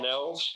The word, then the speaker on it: Alves: By Stan Alves, is that the pronunciation? – Stan Alves, Alves. So,